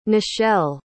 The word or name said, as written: nishelle